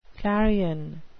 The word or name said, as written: clarion